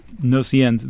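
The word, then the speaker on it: Gnossiennes: Let's hear the second one of the Gnosiens, and I'm sure I'm not pronouncing that right. – Gnosiens.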